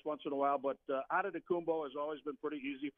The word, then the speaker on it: Adetokounmpo: The Greeks added the T's and the K's and all of that and made it a little difficult. So Adetokounmpo is pretty phonetically easy with the Nigerian spelling.